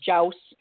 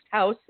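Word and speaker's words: Jaus: I'm sure that most people here think it's Jouse, you know, because of the J. But you do say very clearly on your website, it's the logo, and then down at the bottom, it's pronounced house, H-O-U-S-E.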